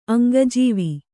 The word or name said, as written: ಅಂಗಜೀವಿ